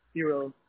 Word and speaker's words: Huro: Yeah, it's H-U-R-O, Hero. – H-U-R-O? – Yes. – Okay. And first name, you do spell it J-A-C-O-B.